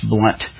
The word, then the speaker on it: Blount: In 1499, he was invited to England by William Blount, I think it's pronounced Blunt, the fourth barony of Mountjoy.